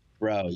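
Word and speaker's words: Breaux: Do I pronounce it Bro or Bria? – Bro, yeah.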